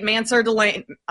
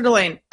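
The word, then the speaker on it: Mansoor Delane: So you traded up to get Mansur Delane? Did I say that correctly? – Delane, yeah. – Mansur Delane.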